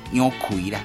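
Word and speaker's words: 杨逵: 日本时代吼，咱台湾有一个足出名小说的作家啦，伊叫做杨贵哦，杨歌舞伊奎杨奎啦，应该是读作奎的款吼。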